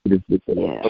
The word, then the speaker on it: DuCros: Is that pronounced DuCros, or do you guys Americanize your last name? – It's DuCros, so it's French. My dad's from New Orleans. – DuCros.